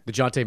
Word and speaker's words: Dejounte: The problem is, like, DeJounte, is that how you say it? – DeJounte Murray.